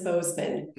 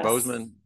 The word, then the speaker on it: Bozeman: You, you pronounce it Bozeman, or how do you pronounce it? Bo- it is, it is Bozeman. – Yeah, yes. Bozeman, Bozeman, yes.